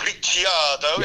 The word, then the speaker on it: Ricciardo: And I think Danny Ricciardo, or Ricciardo, yes, if we're going by NRF1 podcast. – Yeah, by correct pronunciation generally, yes, yeah.